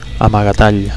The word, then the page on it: amagatall: Catalan